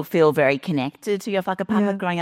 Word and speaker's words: whakapapa: So the W and the H are pronounced as an F. So it's whakapapa. So that is essentially the gift of genealogy, the gift of what makes us Māori.